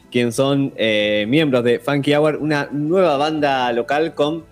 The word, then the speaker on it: Funky Hour: Eh, no sé si la pronunciaré bien, ahí nos dirán ellos. Funky Hour, ¿está bien? – Perfecto.